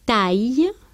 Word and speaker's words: taille: Pero cuando van delante de una palatal, una l o una y, no se forma ese diptongo e, sino que se pronuncian separadamente la a y la i. Lo mismo le pasa a la e y la i. De modo que decimos taille, travail, ailleurs. Ay, no pronunciamos como una E, cuidado.